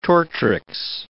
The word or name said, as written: tortrix